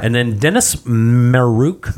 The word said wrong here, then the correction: Maruk: I had him on my list, Maruk.